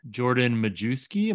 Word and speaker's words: Majewski: We now welcome in Jordan Majewski. Am I saying that correctly? – Hopefully, Majewski. Yeah, silent W.